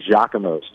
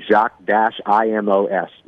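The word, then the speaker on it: Jacques-Imo's: Giacomo's, and it's spelled like Jacques, the way that you would spell like French Jacques. You've got to be careful because there's a Giacomo's Pizza as well. But Jacques-IMOS, Imos, Giacomo's, that's the spot to go where if you want, you know, shrimp and alligator sausage cheesecake, which isn't actually cheesecake at all, phenomenal.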